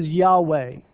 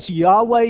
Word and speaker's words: Yahweh: The first part, the J and the O, actually the name of God, we think, or scholars think, that it may have been pronounced as Yahweh.